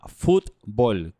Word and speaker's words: fútbol: Eh, esto se llama fútbol, con acento en la U. – Fútbol. Con U, con U, no con, o.